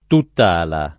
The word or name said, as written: tuttala